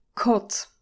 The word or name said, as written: cot